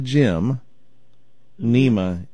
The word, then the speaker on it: Gymnema: G-Y-M, like I'm going to go to the gym, Nema, N-E-M-A, but it's just one word, Gymnema.